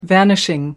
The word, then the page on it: vanishing: American English